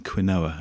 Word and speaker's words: quinoa: Yeah, eating kale and quinoa. Or is it quinoa? Is that how you say it? – Quinoa. – Quinoa, not quinoa.